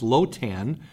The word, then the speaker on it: Lotan: Now, a similar word to Leviathan, which would be pronounced Lotan, occurs in Ugaritic mythology.